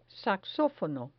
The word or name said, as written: saxofono